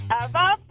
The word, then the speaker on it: Ewa: Okay, so it's Eva, but it's spelled E W A. – E W A instead of a V.